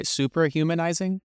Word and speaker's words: Suprahumanizing: Make sure I'm pronouncing this right. Suprahumanizing? – Yeah.